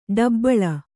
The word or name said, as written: ಡುಬಲಿ